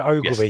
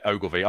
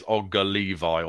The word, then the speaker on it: Ogilvie: Connor Ogilvie. – Yes. Yeah, Ogilvy. I was pronouncing it as Ogga Levi or something in the green room and you were laughing at me.